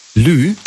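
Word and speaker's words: lieu: Lü? Also Lü, das schreibt man also L-I, E und dann U.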